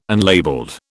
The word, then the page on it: unlabelled: British English